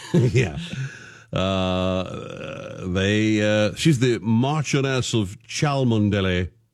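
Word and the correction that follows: Cholmondeley: Marchioness of Chumley. Oh, Chumley's how that's pronounced. – That's even worse. – It's spelled C-H-O-L-M-O.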